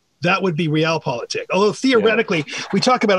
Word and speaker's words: Realpolitik: Okay, another German word you discuss in your book is Realpolitik. Am I pronouncing that correctly? – That's how I pronounce it.